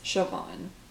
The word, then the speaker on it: Siobhan: So we took a pause, and I ended up looking up how to say the name of the teacher, and it's Siobhan.